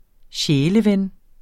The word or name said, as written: sjæleven